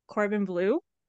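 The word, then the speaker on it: Corbin Bleu: Can you say his name again? – Corbin Blue. – Oh, I thought you said Cordon Bleu, like Yeah. – with a D, and I was like, please keep saying this.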